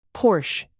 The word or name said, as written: porsche